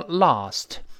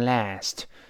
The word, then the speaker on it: last: 那你昨天晚上给小笨笨打电话了没有啊？Last night，这个 last 鲜明的美音，英国人读成 lost，美音中啊变成 a 读成 last，最后那个 t 失去爆破，因为 t 是爆破音，night 又是另外一个辅音呢开头的，失去爆破了。